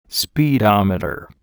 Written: speedometer

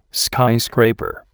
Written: skyscraper

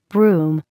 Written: broom

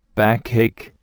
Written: backache

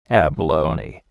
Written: abalone

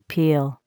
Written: peel

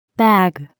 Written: bag

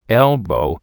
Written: elbow

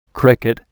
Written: cricket

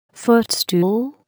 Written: footstool

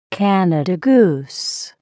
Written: Canada goose

